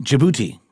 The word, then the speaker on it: Djibouti: Djibouti. Yes, that's the D is silent.